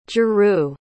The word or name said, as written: jurou